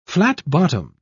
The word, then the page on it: flat-bottomed: British English